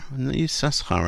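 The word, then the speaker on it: Issachar: Na'ama Yisachar, Yisachar's mother, is it Yisachar? Yisachar, I think they pronounce it.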